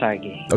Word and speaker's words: TAGEH: Now I know that you started another business called TAGEH. Did I pronounce it correctly? – Yes, it's TAGEH.